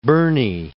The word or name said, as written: Burney